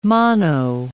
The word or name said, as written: mono